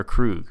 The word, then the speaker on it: Kruge: So, how do you say Krug? Do you say Kruge or Krug? – I've always said Krug. Commander Krug.